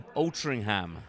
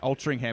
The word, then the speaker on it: Altrincham: Altrincham. – Altrincham. And it's spelt nothing like that, is it? – But, no.